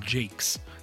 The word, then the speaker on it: Jacques: It always trips me up saying his name, because it's spelled Jacques, like Jacques Cousteau, but that's exactly the spelling, Jacques, but it's apparently pronounced Jakes.